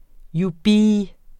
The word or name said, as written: jubi